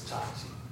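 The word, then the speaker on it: chachi (チャーチ): We use the word chachi, or church, in the Japanese pronunciation is chachi.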